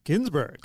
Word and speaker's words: Gunsberg: Gunsberg? – The other day said Ginsberg. – Ginsberg. – I thought it was Gunsberg. – Right. – But he pronounces it Ginsberg.